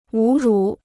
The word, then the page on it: 侮辱: wǔ rǔ